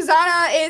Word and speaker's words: Zana: But yeah, so Zana is a English pronunciation of my full name, Susana, in Spanish.